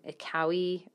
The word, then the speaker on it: Kaui: Kaui or K-A-U-I. So I guess it's maybe rhymes with Maui.